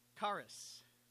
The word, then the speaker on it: charis: Grace is from the Greek word charis. Now, it's spelled C-H-A-R-I-S, where we get the word charisma, this idea of charity.